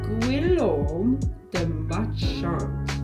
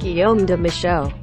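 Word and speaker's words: Guillaume de Machaut: Fun fact, Flo did absolutely butcher that name. It's actually pronounced Guillaume de Machant.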